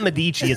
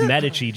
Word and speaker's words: Medici: Like, it's not Medici. It's Medici. Just say it right.